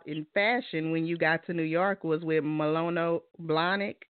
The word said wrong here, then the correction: Manolo Blahnik: Manolo Blahnik.